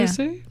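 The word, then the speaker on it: Osé: It's like O-S-E with a little accent on the E, like yeah. – Ose?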